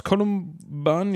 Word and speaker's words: Kolumban: Kolumban war, spricht man ihn so aus? Kolumban, ja. Kolumban, ne? genau.